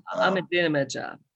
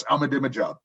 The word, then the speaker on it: Ahmadinejad: You know, okay, so first of all, the Ahmadinejad, I forget how to say his name. – Ahmadinejad. – Yes, Ahmadinejad.